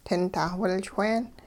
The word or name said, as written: tintah-wilchween